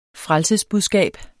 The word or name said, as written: frelsesbudskab